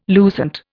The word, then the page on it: lucent: Indian